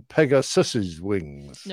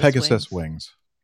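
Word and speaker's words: Pegasus' wings: Should it be Pegasus wings or Pegasus's wings? – No, Pegasus. – Pegasus wings.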